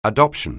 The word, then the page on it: adoption: anglais